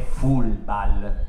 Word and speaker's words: Fulbal: Allora, il libro, la pronuncia esatta è Fulbal, alla piemontese, non all'inglese, perché Fulbal era il modo con il quale mia madre chiamava il gioco del calcio.